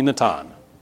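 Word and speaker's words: Natan (נתן): In the same way that different names, my name is Nathan, but if I travel to Israel, everybody's going to call me Natan, right? That's what they're going to call me. That's how you say Nathan in Hebrew. If I were in Israel, they'd call me Natan, right?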